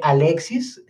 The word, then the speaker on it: Alexys: Mira, mi nombre se escribe Alexis con Y, o sea, A-L-E-X-Y-S.